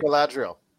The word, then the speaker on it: Galadriel: I can never pronounce her name. – Galadriel. – Thank you. Why it took Galadriel so long.